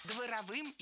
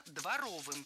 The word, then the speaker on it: дворовым: Там отец был тиран чудовищный, и братья друг друга тоже, ну, выражаясь таким уж дворовым языком, чморили, да, старший там Антошку гонял. – Дворовым языком – это неверно, правильно, дворовым. В прилагательном, образованном от существительного «двор», ударение ставим на второй слог.